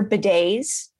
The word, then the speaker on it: bidet: And you've got your bidets. Bidets are extremely hygienic. – That word itself, bidet, you don't pronounce the T.